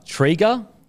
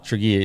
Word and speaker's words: Tregear: IGA Liquor Trigger, so that's T-R-E, Trigger, Trigger, maybe. So head into IGA Liquor Trigger. It's spelled T-R-E-G-E-A-R. You boys know it, how to say that? – No, sorry.